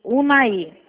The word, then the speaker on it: Unaí: Eu pronunciei certo? É Unaí ou Unai? – É o Naí mesmo. – Unaí.